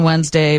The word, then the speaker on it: Wednesday: Most Americans don't pronounce the first D in Wednesday, but there it is.